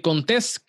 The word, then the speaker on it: Contés: Mi nombre es Miguel Contés, con acento en la E, y nos vemos en la próxima.